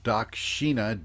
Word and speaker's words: Dakshina: They're actually Sanskrit prayers set to music, and uh, the album that I'll suggest of, for you of hers is called Dakshina, D-A-K. S H I N A, Dakshina.